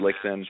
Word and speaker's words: Lichtin: I don't like saying that anymore, but it's J-A-R-E-D. And then my last name is Lichtin, L-I-C-H-T-I-N.